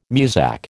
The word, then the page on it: Muzak: American English